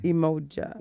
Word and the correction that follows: Umoja: So it's U, but it's pronounced as U. – U. – Umoja. Like in French.